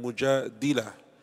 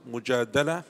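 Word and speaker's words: Mujadala (مجادلة): And it is pronounced Mujadala with a fatha on the dal, and mujadila with a kasra on the dal.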